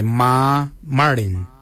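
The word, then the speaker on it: marrdiny: Ma Kuju is spelled M-A-R-R, then it's G-O-O-D-J-A-L. For 8 we say Ma Marin. Ma Marin is number 8. Mar is spelled M-A-R-R, then it's M-A-R-R-D-I-N-Y. Ma Marin for number 8.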